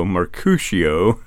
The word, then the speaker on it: Mercutio: Mercutio, I guess? – Mercutio. – You're performing the role of Mercutio. – It might be easier in French. – What is it in French? – Mercutio.